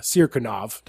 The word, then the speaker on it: Sirkunov: Sirkunov.